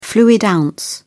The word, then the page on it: fluid ounce: British English